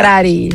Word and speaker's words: Puerari: Então, gente, nós estamos aqui batendo papo com a Lucy Puereri. Falei certo agora. – Puerari. – Puerari. – Like Ferrari.